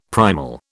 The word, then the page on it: primal: British English